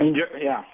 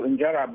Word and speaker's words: injera: And I love the teff bread injera, how is it pronounced? – Injera.